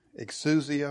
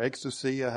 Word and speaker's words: exousia: The word domain there is exousia or exousia, however you want to pronounce the Greek.